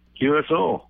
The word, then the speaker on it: QSO: You were one of my very first QSLs, right? Is that how you say it? – Yep, QSO.